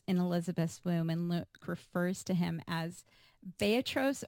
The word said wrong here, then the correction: brephos: or can you pronounce it for me? Brephos.